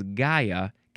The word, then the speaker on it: Gaia: We have this one we want to pronounce the Gaia. Previous episode we had Gaia? Where did it go? No, that one was Gia. Totally different. This one is Gaia, capital G-A-I-A.